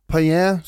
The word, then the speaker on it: Payen: Men den mest intressanta reseberättelsen från Sala kommer ju från Nicolas Payen, hur man nu uttalar det, en till fransman som var i Sverige på 1660-talet. Det är några år efter Ogier är där. – Ja, Payen, sa vi det? – Ja, vi köper det.